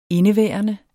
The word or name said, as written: indeværende